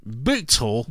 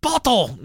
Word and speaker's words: Bootle: And some of our people listening from over the pond are saying, "You're pronouncing it all fucking wrong. It's bottle." No, that's not how we spell bottle in America. It's you got it spelled like bootle. Looks like poodle, but not poodle. It's bootle.